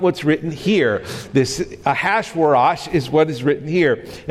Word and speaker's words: Ahasuerus: Now, in the days of Ahasuerus, that's how you pronounce that name, Ahasuerus.